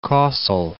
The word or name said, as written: Kossel